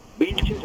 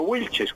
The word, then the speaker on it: Vilches: Mariano Vilches en español, Vilches en español y no Wilches como pronunciamos los colombianos.